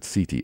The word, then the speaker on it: SETI: Lawrence is actually a member of, and I'm going to pronounce it CETI, S-E-T-I Institute, but also a tremendously well-respected scientist with numerous good old-fashioned, regular, scientifically reviewed scientific papers.